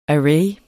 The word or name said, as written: array